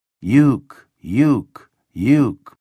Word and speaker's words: Youkilis: It sounds like they're booing the kid when he walks to the plate, but the grin on Youkilis's face says he knows better. That sound sweeping around the ballpark like a soft wind is the first syllable of his last name. Uke, Uke, Uke.